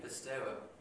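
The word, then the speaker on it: Pisteuo (πιστεύω): Pisteuo, am I close? Pisteuo. Say it again. – Pisteuo, it's eu. Pisteuo. Okay, there it is.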